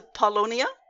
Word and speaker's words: Paulownia: But I'm so glad you brought up it's a Paulownia. Is that how you pronounce it? Okay. – Yeah.